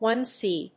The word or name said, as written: one C